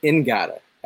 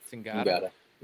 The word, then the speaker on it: Ngata: It's Ngata. Actually, that's how it's pronounced. – Ngata.